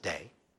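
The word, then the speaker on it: day (δεῖ): It's the word day. And I have friends that will argue with me on how it's pronounced, but it's just three letters and it's pronounced day. You know who I'm talking about.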